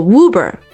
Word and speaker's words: Uber: 怎么说呢，很容易读成 Uber，但其实不是的。 – It's a German word, not an English word。 – 不是一个英语单词哈，是一个德语单词，所以直接读作 Uber。